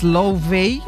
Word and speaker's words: Laufey: Esa voz maravillosa islandesa es Laufey, o algo así.